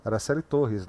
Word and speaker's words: Araceli Torres: que é a Araceli Torres. Falei certo o nome dela, Leno? Araceli Torres, não é isso? Isso.